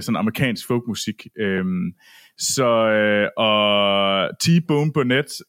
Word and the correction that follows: Burnett: øh, eller Burnett øh, er det nok nærmere det udtales øh, han har godt nok lavet nogle gode ting, øh, må man sige.